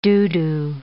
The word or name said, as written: doo-doo